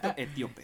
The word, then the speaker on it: etíope: Me va diciendo que ella conocía, que no conocía, vamos discutiendo, vámonos adentrando de este Jesucristo eh, etiopio. E, etiope. ok. Etíope.